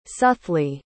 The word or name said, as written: suthley